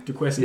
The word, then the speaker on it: Duquesne: Duquesne. It's pronounced Duquesne from CSI Miami. – Duquesne. – Yeah, similar to Dufresne.